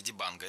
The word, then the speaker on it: Дибанго: Дибанго. Это, кстати, очень интересный момент. Естественно, во франкоязычных странах и французы ударение всегда перенаправляют на последний слог, но я спросил, как говорить надо, вот, а африканцы говорят Маню Дибанго.